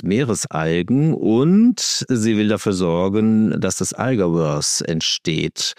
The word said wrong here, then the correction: Algiverse: Habe ich das richtig ausgesprochen? – Algiverse, sagen wir meistens.